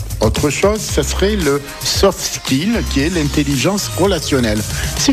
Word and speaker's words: soft skill: On va parler du soft skill, si je prononce bien. – Oui, soft skill.